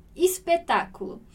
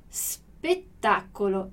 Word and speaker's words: spettacolo: Em italiano, a gente não tem essa mudança e esse S aqui, ele é mudo. Então, spettacolo. Dillo chiaro, spettacolo. Ok, então, importante a gente fazer esse som do S mudo. A gente tem uma tendência muito grande a pronunciar, a colocar um E ou um I ali, né?